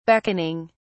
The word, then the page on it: beckoning: British English